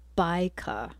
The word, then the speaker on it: Bajka: Radio Citizen, Berlin-based band, but I always jump at the chance to play a band with vocalist Baika, who I've called her Bajka in the past, but I just found out that it's Baika. So if anyone's looking it up, it's B-A-J-K-A, pronounced Baika.